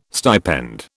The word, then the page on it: stipend: British English